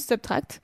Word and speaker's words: SBTRKT: Donc, pour ceux qui cherchent Subtract, c'est S-B-T-R-K-T. Voilà, ça se prononce Subtract.